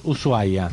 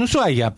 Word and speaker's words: Ushuaia: Igual son creencias, porque viste, a veces de golpe entras, Ushuaia, sí. Puede entrar. Que me encanta que se escriba SH, pero no se pronuncia, la H es muda.